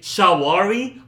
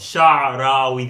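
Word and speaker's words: Shaarawy: Sha-ra-wi. The R comes before the W.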